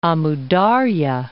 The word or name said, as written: Amu Dar'ya